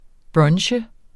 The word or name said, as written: brunch